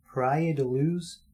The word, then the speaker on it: Praia da Luz: And they were on vacation in a little village called Praia da Luz, Portugal. I looked up how to pronounce that, actually. So that is correct.